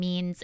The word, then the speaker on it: Means: My name is Lindsay Means with an underscore, and Means is spelled M-E-A-N-S.